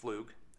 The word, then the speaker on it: Pflug: Ian Flug, and it's pronounced Flug, but it's actually spelled P-F-L-U-G.